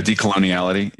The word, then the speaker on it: decoloniality: Decolon- yeah, decoloniality.